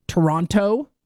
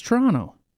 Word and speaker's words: Toronto: They say Toronto. It's Toronto.